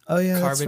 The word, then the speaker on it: Charybdis: I don't even know if I'm pronouncing this right, but Scylla and Charybdis.